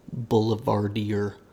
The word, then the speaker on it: boulevardier: It's a boulevardier. – Oh. – No, you said it right. – Okay. – You over-enunciated.